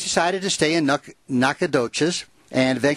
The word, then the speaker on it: Nacogdoches: Yeah, Nacogdoches.